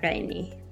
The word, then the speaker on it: Gheini: Yeah, it's Mozhdeh Gheini.